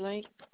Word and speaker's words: Link: Gracie Link. – Gracie, let's see. Okay. May you spell that last name for me? – L-I-N-K.